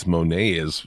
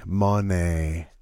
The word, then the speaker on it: Moné: It's like, holy fuck, it's like Sasha Banks or Mercedes Moné is fucking tiny. – Yeah. Jim, you're saying it wrong, by the way. – Is it Moné? – Moné.